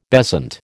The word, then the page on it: bezant: American English